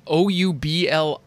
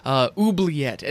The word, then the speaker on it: Oubliette: And yes, that's how you pronounce that. It's Oubliette. Oubliette. And the only reason that I know how to pronounce that is because of Hoggle from the Labyrinth.